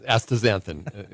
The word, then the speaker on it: astaxanthin: Xanthosin is astaxanthin? – Yeah, good job. Yeah, astaxanthin, exactly, yes. – And what exactly is that? – Astaxanthin, you probably know better, as a compound that makes salmon pink and lobster red.